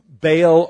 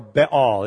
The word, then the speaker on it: Baal: Because you see, the Canaanites worshipped a god by the name of Baal or Baal. It can be pronounced both ways, but Baal was a god of fertility that was thought by the pagans to own the fields, to own the land.